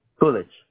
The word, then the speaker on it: Coolidge: Village Avenue. – Coolidge. – Can you spell that for me, please? – C-O-O-L-I-D-G-E.